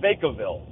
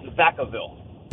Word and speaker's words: Vacaville: Okay, so you guys call it Vacaville. It's Vacaville. – Well, we've gotten Vacaville was probably the number one sent to me and called about, so we know Vacaville.